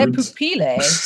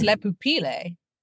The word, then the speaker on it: Le Pupille: Also, we've been saying it wrong because it's not French, it's Italian. – I don't know how to say words. – Le Pupille. What if it's Italian? It's Le Pupille.